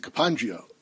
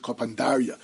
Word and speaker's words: kapandaria: Some pronounce it kapandria. I think the more correct pronunciation is kapandaria.